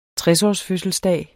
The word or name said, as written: tresårsfødselsdag